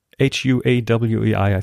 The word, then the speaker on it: Huawei: was about Huawei or H U A W E I.